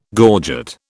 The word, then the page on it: gorget: British English